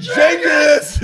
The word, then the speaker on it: Genghis: So, did you say Genghis? Write that down. Hard G. Write that down in the book. Hard G. Yep. Put a line over it.